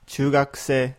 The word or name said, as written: chuugakusei